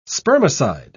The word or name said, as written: spermicide